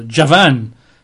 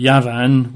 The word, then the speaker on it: Yavan: But the Hebrew is actually Javan, or Yavan. So that's J-A-V-A-N. Or they probably pronounce it Yavan, Y-A-V-A-N, Javan.